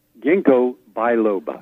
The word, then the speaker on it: ginkgo biloba: Ginkgo biloba. – Biloba. – G-I-N-K-G-O, ginkgo biloba, B-I L-O-B-A.